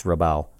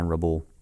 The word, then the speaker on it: Rabaul: And that's actually the way you're supposed to pronounce it. It's Rabaul, not I was saying Rabaul. Sorry about that.